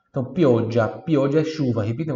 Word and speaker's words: pioggia: Se escreve G-I-A-L-L-O. G-I-A-L-L-O. Giallo, giallo. Pioggia. Pioggia, você já percebeu que se tem um G ou dois Gs, não muda a pronúncia, praticamente não, não muda a questão do, do D, ela vai estar sempre presente ali, tá? Pioggia, pioggia, budia, pioggia, ele é um, é um pouco mais longo só, né?